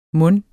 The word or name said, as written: mon